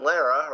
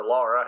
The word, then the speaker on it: Lara: Jor-El and Lara, or Laura, however you want to pronounce, were discussing the pros and cons of sending baby Kal-El to Earth as Krypton's destruction rapidly approached.